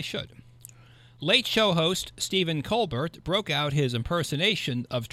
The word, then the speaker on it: Colbert: Colbert, I think it's Colbert, isn't it? Well, that's okay. We can use the French pronunciation or Americanize it to Colbert, but I do believe Colbert is correct.